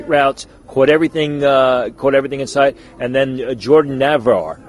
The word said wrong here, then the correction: Najvar: I think we're pronouncing his name right. – I think it's Najvar. – Najvar, there we go, the Baylor tight end.